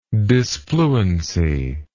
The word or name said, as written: disfluency